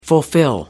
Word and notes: fulfil: American English

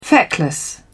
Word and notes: feckless: British English

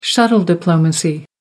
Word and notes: shuttle diplomacy: American English

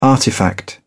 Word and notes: artifact: British English

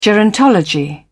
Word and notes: gerontology: British English

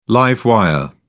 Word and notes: live wire: British English

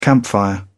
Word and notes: campfire: British English

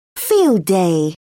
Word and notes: field day: British English

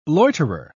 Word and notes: loiterer: British English

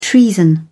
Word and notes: treason: British English

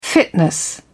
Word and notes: fitness: British English

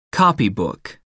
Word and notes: copybook: American English

